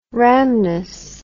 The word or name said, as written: rhamnus